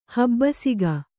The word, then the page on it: ಹಬ್ಬಿಸಿಗ: kannada